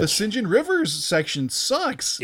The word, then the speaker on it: St. John: I think, like, yeah, they pronounce it St. John in Merry Old England.